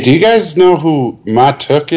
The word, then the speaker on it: Mahtook: M-A-H-T-O-O-K. – M-A-H-T-O-O-K. Matuk.